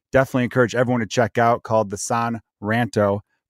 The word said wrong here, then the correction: Sun Ranto: podcast, or the Sun Ranto podcast, I should say. I've talked about this with him. I always want to say San Ranto because San rhymes with Ron, like our guy Ron Santo.